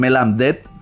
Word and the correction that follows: Bab el Mandeb: creo que era el nombre de Etiopía, el estrecho que está allí. Les voy a decir el nombre correcto. Siempre me pasa. Bab el Mandeb es el estrecho que está allí.